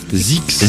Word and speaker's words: Zzyzx: Zix. – Zix. Alors ça s'écrit Z Z Y Z X.